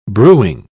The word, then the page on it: brewing: British English